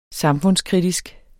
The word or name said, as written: samfundskritisk